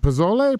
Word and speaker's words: pozole: And the pozole, how do you pronounce it? Pozole? Pozole? – I think it's pozole. – Pozole. That's what I thought.